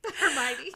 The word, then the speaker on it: Hermione: I don't know how to say this. Harry Potter or Hermione? – Hermione? – That's her name? Yes. Hermione.